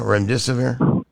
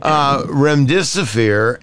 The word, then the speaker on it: remdesivir: We broke the story about remdesivir. Am I saying that right now? Remdesivir? – You are. That's perfect. – Remdesivir and the dangers of it.